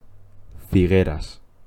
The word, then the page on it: Figueras: Spanish